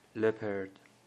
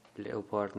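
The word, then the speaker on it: leopard: لپرد، لئوپارد نخونید، لپرد به معنی پلنگ.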